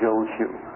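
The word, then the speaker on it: Jōshū: One of the great Chinese Zen masters about 1,200 years ago, in Japanese the name is pronounced Joshu.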